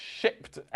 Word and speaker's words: Shipt: We have to be a little careful in our pronunciation of this particular piece of software. Yes, Shipt. let's try that again. Shipt.